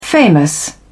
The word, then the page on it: famous: British English